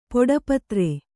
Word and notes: ಪೊಡಪತ್ರ: kannada

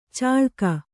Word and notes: ಚಾಳ್ಕ: Kannada; cāḷka

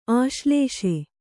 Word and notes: ಆಶ್ಲೇಷೆ: āślēṣe

